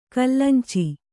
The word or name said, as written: ಕಲ್ಲಂಚಿ